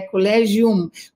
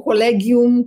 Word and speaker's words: Collegium: Canto, canto, cantorum é, colégio, não é colégio, às vezes é, confunde, né, com o português brasileiro, a gente fala colégio, não é colégio, é colégium, colégium, ou colegium, como se fala em certos lugares, quer dizer associação.